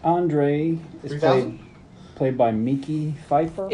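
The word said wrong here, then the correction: Mekhi Phifer: It's Mackay. – Mackay Pfeiffer.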